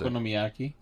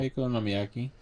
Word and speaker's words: Okonomiyaki: Okonomiyaki. Yeah, Okonomiyaki.